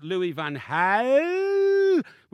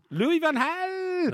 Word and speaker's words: Louis van Gaal: And I don't think that Louis van Gaal, which is the way it's pronounced in Holland. – No, it's not. – Yes, it is. – It's not. – Louis van Gaal.